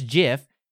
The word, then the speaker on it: GIF: I mean, just like GIF is the correct pronunciation of that word of animated images that we share on the internet. It is GIF. Yes, like the peanut butter.